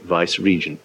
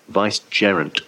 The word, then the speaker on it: vicegerent: He was vicegerent in spirituals, a title no one's ever held in England before, so everyone always mispronounces it vice-regent. No, vicegerent.